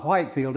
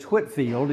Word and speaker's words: Whitefield: Even though he's got an E in his name, it's not Whitefield, it's Whitefield. You don't pronounce the E.